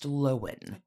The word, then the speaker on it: Lohan: We learned this week that her name is pronounced Lohan.